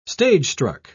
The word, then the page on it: stagestruck: British English